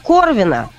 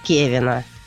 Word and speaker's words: Кевин: Там чисто произношение, да. Вот его перевели как Корвина, а правильно было бы перевести его как Кевина. То есть это его просто имя, это просто набор букв.